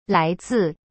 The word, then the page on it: 来自: lai zi